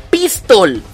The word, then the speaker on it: Pistol: Pronúncialo como se llama la técnica. Gomu Gomu no pistol. Pistol, está en inglés.